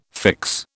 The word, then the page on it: fix: British English